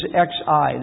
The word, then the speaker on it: Xi: His first name is XI. That's Xi.